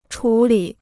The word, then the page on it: 处理: chǔ lǐ